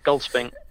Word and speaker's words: Goldspink: How do you say it? – My surname? – Yeah, because I don't want to say Goldspink. – Yeah, Goldspink. – Okay, so it's not like break it up, Goldspink.